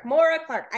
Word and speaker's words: Moira Clark: So I will let her kind of introduce herself, but welcome Moira, how do you pronounce it again? – Moira Clark.